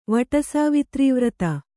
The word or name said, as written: ವಟಸಾವಿತ್ರೀವ್ರತ